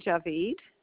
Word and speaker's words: Javid: Sure, it's Roxanne Javid.